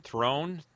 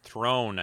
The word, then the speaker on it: Throne: Yeah, that's actor uh, Malachi Thorn, Throne, Throne, I think is how you say his last name, playing uh, Commodore Mendez here.